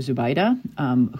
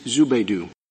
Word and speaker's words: Zubaydah: Okay, so the next case we wanted to debrief was United States versus Abu Zubaydah, whose name the Chief Justice seems to believe is pronounced Zubaydu.